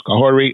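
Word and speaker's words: Kahhori: In the late 15th century, young Mohawk siblings, Kahore and Wata, did I say that correctly? – Yes.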